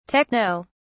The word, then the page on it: techno: British English